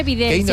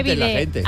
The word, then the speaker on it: bidé: Que no se dice Videl, se dice Bidé.